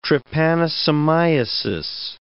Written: trypanosomiasis

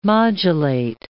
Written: modulate

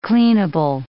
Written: cleanable

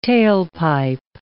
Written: tailpipe

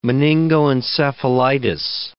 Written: meningoencephalitis

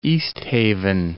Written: East Haven

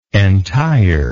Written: entireness

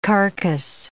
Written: carcase

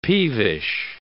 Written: peevish

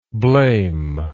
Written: blamer

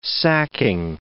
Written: sacking

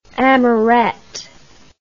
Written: amourette

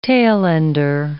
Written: tailender

